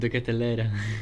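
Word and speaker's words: De Ketelaere: And I think the finalists were Paul Onuachu, Noa Lang, and Charles de Clateret of two players of club. I've butchered that name. – The Catalera.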